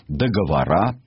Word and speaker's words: договора: Это значит, что польское государство и его правительство фактически перестали существовать, тем самым прекратили свои действия договора, именно с таким ударением, заключенные между Советским Союзом и Польшей.